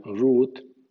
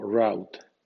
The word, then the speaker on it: route: Así, route puede sonar ROOT o route.